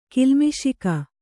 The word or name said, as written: ಕಿಲ್ಮಿಷಿಕ